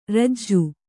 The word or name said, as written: ಋಜು